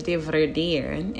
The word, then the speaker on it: dividere: So divide is a Latin word that's actually pronounced as dividere. And excuse me if I'm chopping up that word, but it's originally Latin.